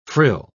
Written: frill

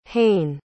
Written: heyne

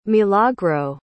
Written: milagro

